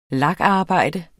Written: lakarbejde

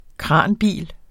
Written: kranbil

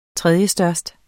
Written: tredjestørst

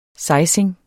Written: sejsinger

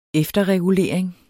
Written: efterregulering

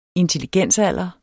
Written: intelligensalder

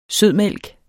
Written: sødmælk

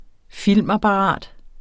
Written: FILMAPPARAT

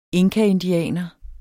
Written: inkaindianer

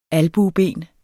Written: albueben

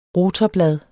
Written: rotorblad

